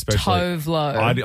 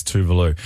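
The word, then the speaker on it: Tove Lo: People always mispronounce her name as Tovlo. – I said Tovlo for ages, but when I got right into her, I realized it's Tuvalu.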